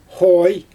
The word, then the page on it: xoj: Xyzyl